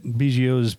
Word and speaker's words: Biggio: Fox says that Biggio's business has been a major impact on the town economy.